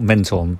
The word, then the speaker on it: Menton: It's spelled Menton, but I believe it's pronounced Menton.